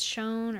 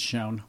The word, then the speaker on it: Schoen: If it's S-C-H-O-E-N. I'm not sure if it's Schoen or Scone. – I think it's Schoen.